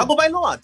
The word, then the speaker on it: Tagovailoa: Pronounce his last name, bruh. – Tua Tagovailoa. Not like the commissioner. The commissioner blew it, and he jacked the name. – Tagovailoa. Come on, it's not hard. – Tagovailoa.